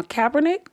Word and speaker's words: Kaepernick: Because before, I don't want to say his name wrong, Kaepernick? – Yeah, Colin. – Colin Kaepernick.